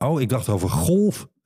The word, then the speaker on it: golf: Golf. Dat wel met een zachte G wordt uitgesproken.